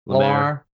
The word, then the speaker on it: Lemar: Talks of Lemar joining us. Am I saying that right? – Lemar.